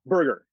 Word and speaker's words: Berger: Berger.